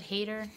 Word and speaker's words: Hader: Is it Hader? – Yeah. – Is that how you pronounce it? – Okay. Yeah, Bill Hader.